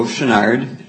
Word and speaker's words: Chouinard: My name is Joe Chouinard. I'll spell that C-H-O-U-I-N-A-R-D, of Maplewood.